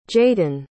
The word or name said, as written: jaydan